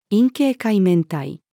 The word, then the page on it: 陰茎海綿体: female